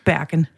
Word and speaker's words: Bergen: Var det udtalt rigtigt? Bergen. – Helt perfekt.